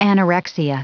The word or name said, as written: anorexia